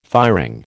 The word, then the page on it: firing: American English